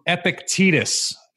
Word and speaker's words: Epictetus: This came from Epictetus. That's how you say it. I Googled it.